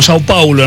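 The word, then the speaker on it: São Paulo: Porque ahora juega con San Pablo, con Sao Paulo en realidad, sí. La correcta pronunciación, sí.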